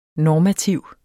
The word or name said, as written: normativ